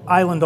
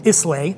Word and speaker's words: Islay: So Isla is a little island off the west coast of Scotland. A lot of people, you'll hear it mispronounced, Islay. – That's what I would have said. – But it's Isla.